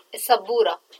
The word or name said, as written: assabura